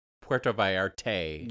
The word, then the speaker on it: Puerto Vallarta: Am I saying that right, though? Because I got shit for that yesterday we were talking about it. Puerto Vallarta, right? – Puerto? Yeah. You were saying Puerto Vallarta.